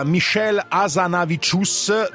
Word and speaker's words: Hazanavicius: Diretto da Michel Hazanavicius, dovrebbe essere questa la pronuncia giusta vista la nazionalità francese del regista, interpretato da Jean Dujardin, vincitore del premio al Festival di Cannes per il miglior attore.